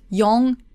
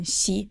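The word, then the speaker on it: shi (四): You might have heard yong and kyu, but there's also pronunciation shi and ku. And shi sounds like the same as death, and ku from that's something that worries you, that troubles you, that makes you feel pain and sorrow.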